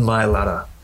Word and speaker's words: Mailata: Jordan Jordan Mailata.